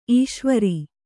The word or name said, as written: ಈಶ್ವರಿ